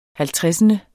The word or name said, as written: halvtredsende